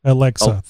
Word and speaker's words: Alexa: Well, that's one of the things that you can see on Alex. I don't know if I'm saying it right. It's Alex with an A dot Alexa. – Org. Alexa. Thank you.